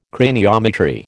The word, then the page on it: craniometry: American English